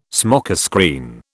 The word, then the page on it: smokescreen: British English